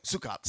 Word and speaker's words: Sukkot: It's called Sukkot. It's basically Sukkot. Sorry. Okay, thanks. Can count on the previous to correct my pronunciation.